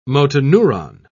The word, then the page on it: motoneuron: British English